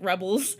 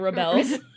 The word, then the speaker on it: rebels: You had the wrong emphasis on the wrong syllable there. you, you said rebels instead of rebels. he rebels against his parents.